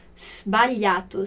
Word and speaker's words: sbagliato: Sbagliato, olha essa pronúncia do sbagliato, sbagliato com SS mudo.